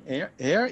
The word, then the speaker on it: Ayr: Don was born in Ayr, is that how you pronounce it? – Mm-hmm.